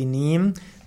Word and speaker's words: Neem: Niembaum wird auch geschrieben N-E-E-M, das ist die englische Bezeichnung. Im Sanskrit spricht man von Nimba. Und daraus siehst du schon Hindi Niem, dass der Niembaum vor allem im Ayurveda sehr wichtig ist.